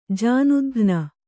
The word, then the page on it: ಜಾನುದಘ್ನ: jānudaghna